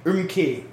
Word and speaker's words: Urumqi: On July 10th, mobile phone users in the Tianshan district of Urumqi, I forgot how Qs are pronounced. – Ks. – Yeah, okay. Urumqi city received a mobile phone notification with a distinct government instructing them to...